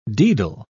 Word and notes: daedal: British English

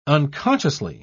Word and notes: unconsciously: British English

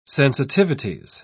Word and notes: sensitivities: British English

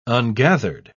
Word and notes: ungathered: British English

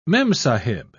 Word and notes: memsahib: British English

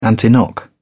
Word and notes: antiknock: British English

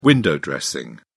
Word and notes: window dressing: British English

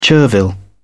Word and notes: chervil: British English

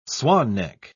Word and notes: swan-neck: British English

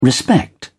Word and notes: respect: British English

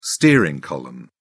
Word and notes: steering column: British English

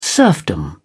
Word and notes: serfdom: British English

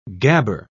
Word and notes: gabber: British English

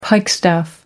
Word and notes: pikestaff: American English

